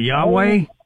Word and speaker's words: Yahweh: You talking about Yahweh? – That's what I mean. That's the way we say it is Gentiles or whatever. But it's spelled it's spelled YHWH, no vowels.